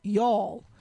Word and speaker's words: Youghal: So, yeah, there's actually a town in Ireland called Y'all, but it's spelled, I think, Y-O-U-G-H-A-L. It's not really, you know, Y apostrophe A-L-L as the proper spelling for y'all would be.